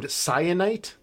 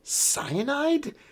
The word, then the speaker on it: Syenite: Our third main character is named Cyanite. That's spelled S-Y-E-N-I-T-E. When you are listening to this book, you spend the first few minutes of her chapter being like, Cyanide? – I had Cyanite like C-Y-A-N-I-T-E.